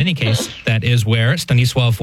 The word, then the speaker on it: Stanisław: And he's asking, who can pronounce Stanislaus, although in Polish it would be Stanisław, of Szczepanów, which is one of his titles.